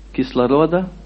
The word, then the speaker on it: кислорода: И необходимо иметь кислорода, правильно, по-русски ударение, и это будет через кровь матери, но нужно воздух, нужно дышать, и в этой форме.